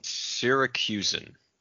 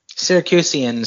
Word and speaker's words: Syracusan: So Syracusan. You were close. Syracuse. You added one extra syllable, I think. – Syracusian sounds better than Syracuse.